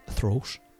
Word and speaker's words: throat: And uh, I said throat there, and I didn't pronounce the TH. There's a, throat.